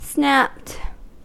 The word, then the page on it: snapped: American English